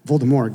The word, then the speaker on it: Voldemort: Well, Voldemort, am I saying that right? Voldemort, got it.